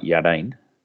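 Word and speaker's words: Yarden: Jordan is actually pronounced more like Yardane.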